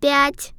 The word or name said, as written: 5